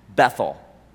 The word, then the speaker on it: Bethel: And this particular company of the prophets, they resided at Bethel, or Beth-El, would likely be a better pronunciation.